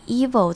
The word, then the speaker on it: evil: evil 前面的 e 发成长母音 e 的声音，然后 vil 的 i 发成跟刚刚 around 那个比较轻的是一样的发音，所以 evil 这个字前面是长的，那后面是轻的短的 evil nighttime visions。